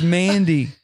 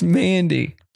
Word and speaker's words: Mandy: It's Mandy, not Amanda. It's Mandy.